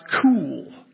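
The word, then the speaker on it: cool (כּוּל): It's the word K-U-L. It's pronounced cool. I thought, oh, that's cool.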